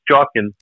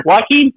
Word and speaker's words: Joaquin: That person I called Joaquin Phoenix, Jawkins. – Oh, I don't know. How do you say Joaquin? – Joaquin Phoenix.